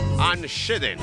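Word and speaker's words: Anne Schedeen: Ann Sheeden. Así se pronunciaba, mirá, no sabía.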